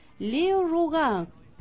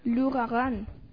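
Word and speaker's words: L'Ouragan: Un día como hoy, 29 de abril de 1901, en París, se estrena la ópera Leo Rougan. ¿Así se pronuncia? – No. – ¿Cómo es? – Lugarán.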